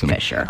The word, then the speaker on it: Fischer: It's F-I-C or F-I-S-C-H-E-R. So yeah, that's Fisher.